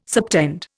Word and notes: subtend: Indian

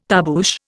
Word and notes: tarboosh: Indian

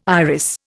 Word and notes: iris: Indian

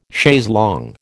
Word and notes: chaise longue: American English

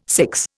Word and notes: six: Indian

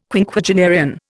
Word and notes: Quinquagenarian: Indian

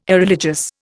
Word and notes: Irreligious: Indian

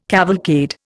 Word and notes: cavalcade: Indian

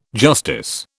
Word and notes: justice: British English

